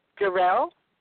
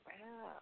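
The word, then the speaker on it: Sherell: Jarell? – Yes, Jarell. – Oh, Jarell. How do you spell it? – Yes. excuse me. S-H-E-R-E-L-L. S-H-E-R-E-L-L.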